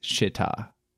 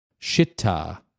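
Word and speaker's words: Schiit: And they say it's pronounced Shitta. – Shitta. – Which does not. – Shitta. – Does not, it's a proud German name.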